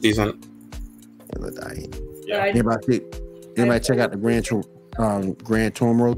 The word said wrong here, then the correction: Gran Turismo: trailer? – It looks interesting. – I think I said that right. – Gran Turismo. – Yeah. – Yeah, my bad.